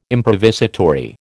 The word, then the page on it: improvisatory: American English